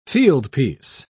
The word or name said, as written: fieldpiece